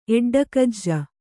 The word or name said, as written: ಎಡ್ಡಕಜ್ಜ